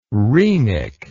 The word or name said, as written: rhenic